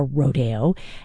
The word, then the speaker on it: Rodeo: Rodeo, or Rodeo, as it's sometimes pronounced.